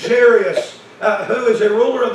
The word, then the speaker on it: Jairus: The Bible says in verse 41, "And behold, there came a man named Jairus," or Jairus, depending on how you want to say that. Jairus is how I pronounce it.